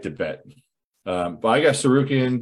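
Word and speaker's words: Tsarukyan: Sarukian. – The T is silent.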